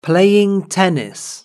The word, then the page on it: playing tennis: British English